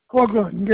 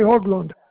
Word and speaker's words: Haugland: Hoagland, Gary. Gary Hoagland. – And how do you spell that? – H-A-U-G-L-A-N-D.